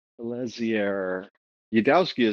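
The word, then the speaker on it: Eliezer Yudkowsky: Elezier Yudowsky is how you would say it.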